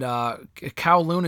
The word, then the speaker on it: Kowloon: I mean, the vignettes with them at Kowloon, is that how you pronounce it? – Yes.